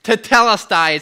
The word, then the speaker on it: tetelestai: Tetelestai is how you pronounce that word.